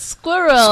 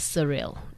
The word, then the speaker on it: Sxuirrel: It's just Squirrel. – Oh, it's Squirrel. – Squirrel, but there's an X. – Okay, so for our guest, it's actually spelled SXUIRREL, and I was pronouncing it as Surreal.